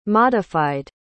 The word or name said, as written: modified